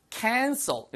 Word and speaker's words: cancel: cancel 발음 하나만 주의하세요. C-A-N-C-E-R 그거는요. cancer 그거는 er 발음을 하시면은 암이란 뜻이고요. cancel ill 발음을 하시면은 취소하단 뜻이에요. 자, 취소하단 cancel. 자, 암은 cancer. 구분되시죠?